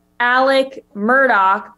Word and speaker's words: Murdaugh: Yeah, I watched the Netflix documentary, and the whole time I was just confused because they were saying Alex Murdaugh, but it's spelled Alex Murdaugh.